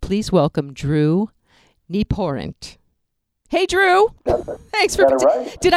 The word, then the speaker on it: Nieporent: Well, but when it came to pronouncing the name, she'd point to her knee, then she'd pour wine, and then she'd pay the rent by sticking her hand out. So it was knee, pour rent.